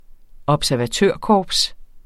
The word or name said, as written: observatørkorps